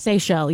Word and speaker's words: Seychelles: Have you ever heard of the, I believe it's pronounced, is it pronounced Seychelles? – Seychelles, yeah. – The Seychelles, the island, islands. – S-E-Y-C-H-E-L-L-E. – I've never been there.